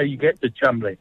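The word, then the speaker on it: Cholmondeley: Instinctively, you'd think Cholmondley. – Jeez, you've stumped me with that one. – Well, okay. In fact, the correct pronunciation is, guess what? It's Chumley.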